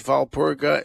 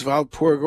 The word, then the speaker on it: Walpurga: St. Boniface was the uncle of St. Walburga, whose names are sometimes pronounced in Old English, it's Waldeburg, in Latin, it's Walpurga, in German, it's Walpurga or Walpurgis, and lots of different ways to say it.